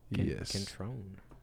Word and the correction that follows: Cintron: Am I saying that wrong? Is it Cintron? – It's Cintron. Cintron, yeah.